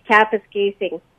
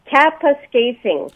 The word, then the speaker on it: Kapuskasing: Hi, it's pronounced Kapuskasing. – Okay, in slow motion, one more time. – Kapuskasing.